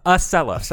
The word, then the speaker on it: Acela: Could you pronounce that again? – The Excella? – The Excella? – Is that not how it is? – You were writing a spreadsheet up to New York? – Excella? – Acela. The Acela. – A-C-E-L-A. – Acela. – Whatever it is. I was taking the train. – It's one C. It's not A-C-C-E-L-A, right? It's Acela.